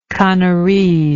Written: canarese

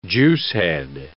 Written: juicehead

